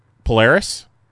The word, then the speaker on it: Polaris: And I always say it wrong. Polaris? – Yeah. – Okay. – Yeah. – Nailed it.